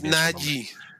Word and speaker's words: Najee: Salió en un video diciendo que pronuncia. – Que es Najee.